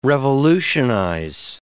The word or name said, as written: revolutionize